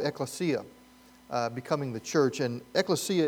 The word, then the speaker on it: ekklesia: EKKLESIA. One more time. EKKLESIA.